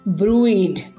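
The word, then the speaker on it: bruised: इसको हम ब्रूइज भी बोलते हैं और कुछ जगहों पे इसमें एस साइलेंट करके भी कुछ लोग बोलते हैं तो इट इज ब्रूइड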